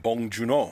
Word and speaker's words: Bong Joon-ho: From Bong Joon-ho.